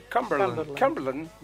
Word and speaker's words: Cumberland: Tropas reales enviadas por el rey Jorge II, lideradas por el duque de Cumberland. ¿Se dice así? – Cumberland.